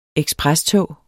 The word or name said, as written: eksprestog